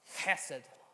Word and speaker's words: chesed: And this word is one word, and it's pronounced chesed. Try to say that with me. Chesed. Now with a bit more phlegm. Chesed.